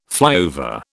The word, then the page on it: flyover: British English